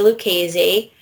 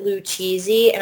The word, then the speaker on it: Lucchese: Or they pronounce it Lucchese, and I'm like, I'm not a freaking cheese.